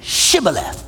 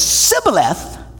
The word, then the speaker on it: Shibboleth: Well, then say Shibboleth. And if the guy said Sibboleth, because he could not pronounce the word correctly, they seized him and killed him in the fords of the Jordan.